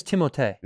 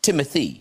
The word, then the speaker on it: Timothée: His name is Timothée. – No, it's not. – Go on YouTube and show the compilation of him saying Timothy the right way. – It's pronounced Timothy. – It's whatever you'd like. – Because I always thought, I don't know if you guys, I thought it was Timothée because of the double E's at the end.